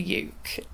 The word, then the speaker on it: uke: I mean, I would refer to it as a uke because it rhymes with puke.